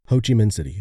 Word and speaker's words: Ho Chi Minh City: And am I pronouncing that right, by the way? – Ho Chi Minh City, yes.